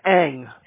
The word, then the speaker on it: Eng: Okay, my name is Ken Eng. The last name is spelled E-N-G,